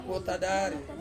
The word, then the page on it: wotadari: Ansus